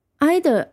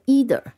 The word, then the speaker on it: either: either 还可以读成 either 啊，两个都可以。